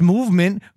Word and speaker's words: MVMT: Movement, pronounced Movement, grew into one of the fastest growing watch brands, shipping to over 160 countries across the globe.